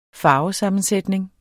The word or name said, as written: farvesammensætning